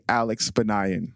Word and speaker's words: Alex Banayan: So guys, please give a very, very, very special roommate welcome to the one and only Alex Banayan.